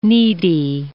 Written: needy

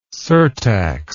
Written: surtax

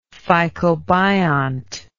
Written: phycobiont

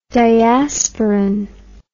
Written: diasporan

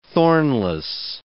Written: thornless

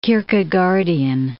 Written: Kierkegaardian

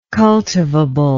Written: cultivable

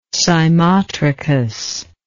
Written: cymotrichy

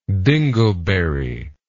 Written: dingleberry

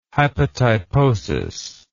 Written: hypotyposis

